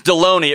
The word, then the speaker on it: Delony: Good, Dr. Delaney. How are you? – Good. It's Delaney. It rhymes with baloney.